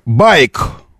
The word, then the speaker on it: BAIC: Итак, значит, мы произносим это следующим образом. Э, байк. Ударение на первый слог. То, что пишется как B-A-I-C, байк.